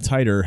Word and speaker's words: Titor: I don't know how you want to pronounce that. It's T I T O R. Uh, John and well, if not, claimed, t- what if not tighter?